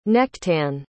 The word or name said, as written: nechtan